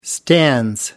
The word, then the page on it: stands: British English